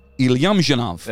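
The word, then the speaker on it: Ilyumzhinov: Ilyumshinov. – That's how you say it. – Ilyumshinov. I got it. Yeah. Ilyumshinov. – Ilyum. Like an idiom, but not the D, it's an L. So Ilyum.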